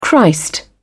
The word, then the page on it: christ: British English